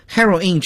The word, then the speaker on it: heroine: 所以我们一说 heroine 这个发音，听起来好像又像女英雄女主人公，也像海洛因啊。